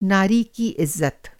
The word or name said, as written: इज़्ज़त